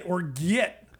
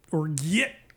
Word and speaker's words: orgeat: You like it, orgeat. – You get it. – It's a sweet syrup made from almond, sugar, and rose water or orange flower water. – And it's pronounced how again? – Orgeat.